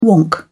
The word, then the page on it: wonk: British English